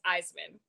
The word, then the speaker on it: Isaman: My last name is pronounced Isaman.